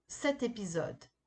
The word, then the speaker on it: cet épisode: Le nom masculin après 7, on prononce le T à la fin, commence par une voyelle et un H muet. Exemple, un homme on dit l'homme. Cet épisode, cet ordi. This, that, man. Episode, computer. Cet est utilisé pour faciliter la prononciation. Grâce au T, on va lier l'adjectif avec son nom.